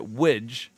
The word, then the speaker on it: widge: It's a bit tenuous, but the only thing I can think of is that widge, and in parenthesis, rhymes with bridge, is Manchester slang for vagina, according to my uni housemate who's from there.